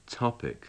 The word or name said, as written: topic